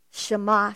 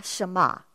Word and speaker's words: Shema: So another way to say endured is, okay, Greg changed the pronunciation of it, Shema, Shema, that's what it is.